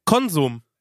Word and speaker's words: Konsum: Und das ist, gibt's da so eine Kette, die, das ist Konsum, ne? Also Konsum, so heißen die. – Ja. – Und die sagen, es heißt aber Konsum. – Nehmen wir auf die erste Silbe.